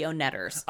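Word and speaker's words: Oneders: I mean the Oneaters, mm-hmm. – all the same downtown. – Hey, by the way, that's the Oneters. – Ah, my mistake. I always pronounce that wrong.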